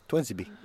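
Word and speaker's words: Tuanzebe: uttales egentlig Tuansebe Tuansibi. Ja, det høres som transsibirsk jernbane på det.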